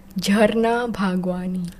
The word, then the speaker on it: Jarna Bhagwani: This is the Hindi pronunciation, yeah. Jarna Bhagwani.